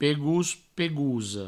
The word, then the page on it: pegós, pegosa: pegous, pegouso; provencal